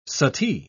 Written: suttee

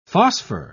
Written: phosphor

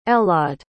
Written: Elaad

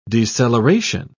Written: deceleration

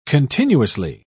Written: continuously

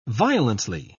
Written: violently

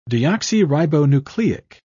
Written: deoxyribonucleic

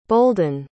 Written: bolden

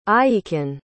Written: aiekin

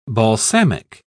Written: balsamic

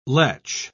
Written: letch

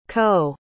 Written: coe

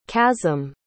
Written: kasim

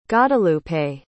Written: godalupe

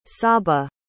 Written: sabah

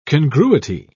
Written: congruity

